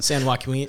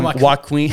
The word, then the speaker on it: San Joaquin: San Joaquin. San, Joaquin. Joaquin. yeah. – Boy. it's uh, Joaquin Phoenix. it's kind of like his name.